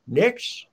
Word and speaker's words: Knicks: but they're the Knicks. Is it? Is a K is silent, right?